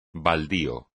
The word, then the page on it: baldío: espagnol